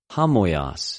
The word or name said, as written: ὁμοιος